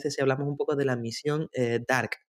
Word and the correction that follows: DART: no, DART. ¿Cómo se pronuncia? – DART. – D-A-R-T. – Correcto.